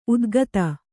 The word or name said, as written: ಉತ್ಖಾತ